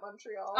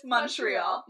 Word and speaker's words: Montreal: Yeah, if there are any Americans or non-Canadians listening to this, and you are unsure of how to pronounce the city of Montreal, it's Montreal, not Montreal. I know you might think it's Montreal, given the M-O-N situation at the front, but it is in fact Montreal. So, nothing makes you sound more like a tourist than saying Montreal.